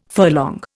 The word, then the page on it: furlong: Indian